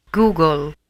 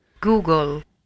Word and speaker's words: Google: Vogliamo risentire la pronuncia in inglese? – Google, Google, Google.